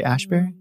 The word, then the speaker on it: Asbury: Did I say it wrong? Did I say Asbury? – No. – Okay. – No, I just second guessed myself.